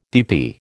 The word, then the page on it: DP: American English